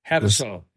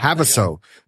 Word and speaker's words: Havasu: and let me know if I'm saying this wrong. Lake Havasu? – Havasu, yeah. – Havasu.